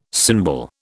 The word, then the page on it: cymbal: British English